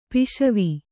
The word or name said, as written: ಪಸಿವು